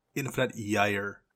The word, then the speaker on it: Infinite Gyre: Matt, I believe the genesis of that word is Swedish, and it's pronounced with a Y. It's Infinite Gyre.